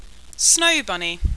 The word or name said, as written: Snowbunny